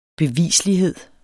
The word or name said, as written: bevislighed